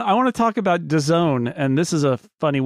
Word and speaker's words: DAZN: It is an event that is happening that a lot of people don't even know exists, in part because it is primarily on DAZN. That is how you pronounce DAZN, by the way.